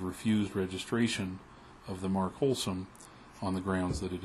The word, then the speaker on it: Holsum: I think of a brand name for bread that I recall having seen years ago, H-O-L-S-U-M. And of course, when you pronounce H-O-L-S-U-M, you get wholesome.